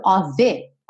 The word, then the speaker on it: of: Esse of, que se escreve OF, sempre, sempre, sempre vai ser pronunciado OV, ok?